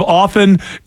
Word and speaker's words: often: Pronunciation of often is silent T.